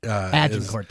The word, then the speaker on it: Agincourt: Agincourt, that's how you say it.